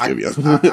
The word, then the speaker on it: Giveon: No, it's really not. It's Giveon. – I corrected you one day. I don't even know what you're talking about. – It's Giveon.